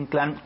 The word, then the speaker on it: Inclán: Los quiero invitar a que nos sigan ahí, a Julio Inclán, mi nombre completo, nada más que fíjese que es el de Julio Inclán con acento en la A.